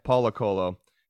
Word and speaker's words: Paulo Coelho: Paulo Coelho. – Yeah. – Paulo Coelho.